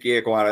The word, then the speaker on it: Ekwonu: Yep, Ike Ekwonu. I think that's how it's pronounced.